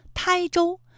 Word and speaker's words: 台州: 它还有一个读音啊，是一声胎，台州，这是我们国家的一个地名哈，还有天台，是一个山名，它都在浙江省。